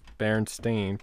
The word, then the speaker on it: Berenstain: While many people think that this writer distinctly remembers spelled Berenstain, pronounced Steen, the actual name of the both bears and their authors, Berenstain, pronounced as it would be Stain.